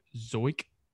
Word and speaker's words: Zeuch: Uh, his name AJ or TJ Zeuch is his last name. – It's spelled Z E U C H. It's German. Zeuch.